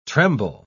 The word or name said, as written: tremble